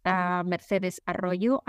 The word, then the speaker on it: Mercedes Arroyo: People can reach me through Instagram, Mercedes Arroyo, and you pronounce my name perfectly.